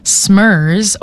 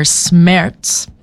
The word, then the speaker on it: Smerz: It is Smerz, or Smerz is how they pronounce it, as it is derivative of the German phrase for heartbreak, which I think is such a fun fact.